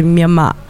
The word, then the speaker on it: Myanmar: And what a lot of European language speakers don't hear is that the Burmese pronunciation of the country is actually Myanmar.